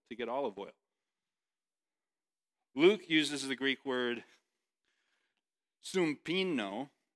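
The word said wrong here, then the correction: sympnigo: which is a little bit different. Sumpino. Sumpino. Or sorry. Okay, let me say it again. Sumpnigo. I was saying it wrong. Sumpnigo.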